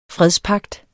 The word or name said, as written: fredspagt